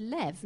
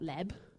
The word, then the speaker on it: lev: Just suffice it to say that when bait is at the beginning of the word, it's usually pronounced b, bay. And when it's in the middle of the word, it's usually pronounced the, ve. Okay? So, lev.